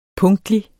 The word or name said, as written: punktlig